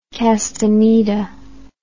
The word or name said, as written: castaneda